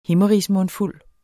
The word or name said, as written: himmerigsmundfuld